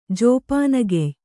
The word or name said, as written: ಜೋಪಾನಗೆಯ್